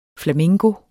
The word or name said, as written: flamingo